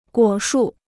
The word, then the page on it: 果树: guǒ shù